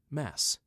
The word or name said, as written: Mass